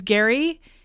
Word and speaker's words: Gerry: Our bachelor's name is Gary. He, which is spelled G-E-R-R-Y, which I hate because that shouldn't be Gary.